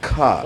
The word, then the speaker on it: Coq: And by the way, if you look into it, you think like, no, it's actually supposed to be pronounced cook, but it turns out, it's not actually. If you actually look into it more, you realize that it's really close to pronunciation about of cook. About cock.